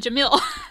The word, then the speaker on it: Jamil: I called her Jameela Jamal. That's not her name. It's Jameela Jamil.